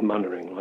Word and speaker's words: Mainwaring: It's spelled Mainwaring, but he probably called himself Mannering, like the chap in the TV programme.